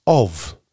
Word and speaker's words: of: Which two-letter word is the only word in the English language where an F is pronounced like a V? I'll give you a clue. It starts with O. Of.